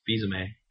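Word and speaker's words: Fils-Aimé: Well, yeah, I know that Reggie Phil Ames really wanted GoldenEye on the Virtual Console. – I pronounce it Fizame.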